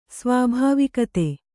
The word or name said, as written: ಸ್ವಾಭಾವಿಕತೆ